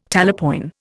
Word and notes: talapoin: Indian

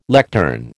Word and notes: lectern: American English

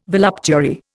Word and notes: voluptuary: Indian

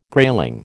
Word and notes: grayling: American English

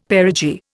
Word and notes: perigee: Indian

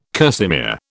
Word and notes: kerseymere: British English

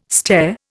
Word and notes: stare: Indian

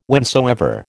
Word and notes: whensoever: American English

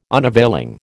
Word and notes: unavailing: American English

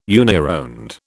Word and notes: unironed: British English

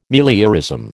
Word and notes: meliorism: American English

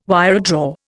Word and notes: wiredraw: Indian

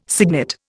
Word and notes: signet: Indian

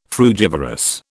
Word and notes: frugivorous: British English

